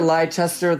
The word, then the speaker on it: Leicester: There's like silent W's. There's like Gloucester, Leicester, like it's all Gloucester, Leicester, Ips. There's silent syllables.